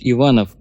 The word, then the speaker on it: Иванов: товарищ Иванов, который с ударением на первый слог?» «Можете его пристрелить», — хмыкнул Ким Петрович.